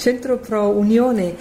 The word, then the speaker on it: Centro Pro Unione: But your pronunciation was quite correct. Centro Pro Unione.